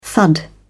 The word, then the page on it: thud: British English